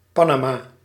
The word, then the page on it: Panama: American English; /ˈpæn.ə.mɑː/